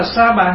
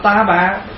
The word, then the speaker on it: sa bà: Nhưng mà chữ ta bà là mình phát âm đúng ra cái chữ mà tiếng Phạn đó gọi là sa ha. Ta bà là sa ha. Thành thử nếu mình nói đúng là mình đọc là sa bà. Bây giờ mình đọc là ta bà, đọc nó chạy rồi.